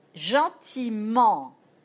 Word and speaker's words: gentiment: We don't pronounce E-N-T. We don't pronounce E-N-T in French. That's the ending. It's a conjugation. But when it comes to an adverb like gentiment, E-N-T, we pronounce it. So remember another rule. Endings or conjugation, present, third person plural, we don't pronounce ENT, but we pronounce it for an adverb, gentiment, or embarquement. Nouns and adverbs, we pronounce ENT in the end, but not the verbs, not the third person plural.